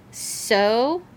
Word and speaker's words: Xochimilco: At the, and I just looked this up right before we started filming this, Xochimilco Canals. Not even spelled like that, like even remotely.